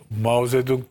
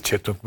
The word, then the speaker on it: Mao Zedong: Hasbelkader Mao Zedong döneminde, bak onu en iyi telaffuz edenlerden biriyimdir Türkiye'de. Herkes Çetong Meçong der. Çinlilerden duymuştum. Çünkü Mao Zedong beyefendinin adı.